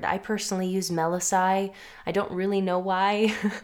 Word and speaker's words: Melissae: And you'll notice that throughout this episode, I say Melissae to talk about the bee nymphs in plural. And in ancient Greek, the word is Melissa for singular and Melissae for plural. But in modern Greek, they say Melissa for a singular bee and Melisses for plural bees.